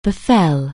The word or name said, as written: befall